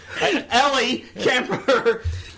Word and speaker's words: Ellie Kemper: Well, I did Eli Kemper, whatever. Wow. – I don't think you got her name right yet. – And you're shocked because... – Ellie Kemper.